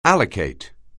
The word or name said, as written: allocate